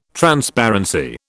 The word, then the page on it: Transparency: British English